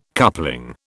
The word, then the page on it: coupling: British English